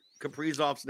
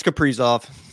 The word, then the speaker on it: Kaprizov: Am I mispronouncing Kaprizov's name also? – You said it. No, yeah, it's Kaprizov. – It's Kaprizov. I've actually heard people say Kaprizov. – They do say it both ways, but I'm 80% sure it's Kaprizov.